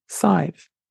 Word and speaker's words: Sive: She was the mother of the warrior Usheen of Tir na n Og in Irish mythology, which means maybe goodness and is pronounced to rhyme with hive, so Sive.